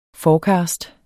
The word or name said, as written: forecast